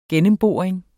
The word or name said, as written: gennemboring